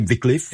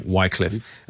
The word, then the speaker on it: Wycliffe: pronounce him Wycliffe. – Exactly, yeah. And we call him Wycliffe.